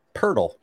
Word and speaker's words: Pirtle: Pirtle, just like turtle.